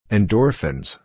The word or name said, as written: endorphins